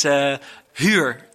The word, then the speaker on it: huur: It's H-U-U-R, H-U-U-R, and it means rent. But Spanish people especially pronounce it as huur. But a huur is a prostitute. So if you say, I'm looking for a huur house, it means you're looking for a house with prostitutes.